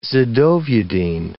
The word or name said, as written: zidovudine